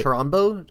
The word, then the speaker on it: Torbano: In Japanese, that final or syllable final nasal would almost certainly. It would have to assimilate to the place of articulation of the ensuing consonant, and so it would be Torbano shores.